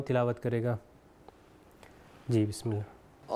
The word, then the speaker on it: اللہ: اللہ، پھر الى اللہ، پھر ان اللہ، یہاں تینوں بار کیا ہوا ہے، ہم لوگوں نے اس کو موٹا تلفظ کیا ہے۔ جی بسم اللہ، کنٹینیو کریے۔